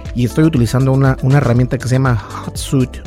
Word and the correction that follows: Hootsuite: o no ¿cómo se dice? Shot, Hootsuite, Hootsuite.